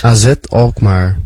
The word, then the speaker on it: AZ Alkmaar: And he said, AZ Alkmaar.